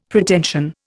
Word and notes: Pretension: Indian

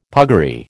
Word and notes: puggaree: American English

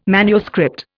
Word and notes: manuscript: Indian

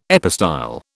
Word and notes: epistyle: British English

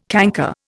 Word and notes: canker: Indian

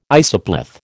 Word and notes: isopleth: American English

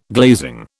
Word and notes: glazing: British English

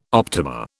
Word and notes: optima: British English

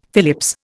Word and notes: Phillips: Indian

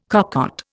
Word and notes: cocotte: Indian

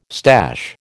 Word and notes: stash: American English

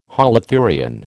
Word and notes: holothurian: American English

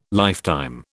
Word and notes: lifetime: British English

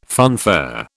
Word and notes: funfair: British English

